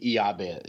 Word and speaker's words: Jabez: I mean, to be fair, in Hebrew, there is no like soft J sound. Mm-hmm. So it would be Yabez. It would be Yabez Hebrew.